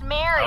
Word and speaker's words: Marie: It's actually spelled Marie, pronounced Mary.